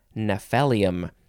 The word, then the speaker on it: Gnaphalium: And that is spelled G-N-A-P-H-A-L-I-U-M. Gnaphalium. I wanted to spell it because it had a couple of weird things going on. Next is the word cue, C-U-E.